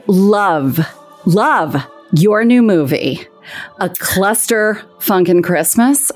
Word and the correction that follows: Cluster Funk Christmas: a, cluster, funk Christmas. – Cluster funk Christmas. yeah, just thank you. I, I added the extra syllable.